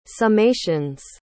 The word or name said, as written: summations